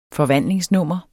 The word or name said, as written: forvandlingsnummer